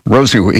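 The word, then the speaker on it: Rosenqvist: I think the V is pronounced as a U. Felix Rosenquist.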